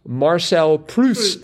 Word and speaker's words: Marcel Proust: And it is indeed Marcel Proust.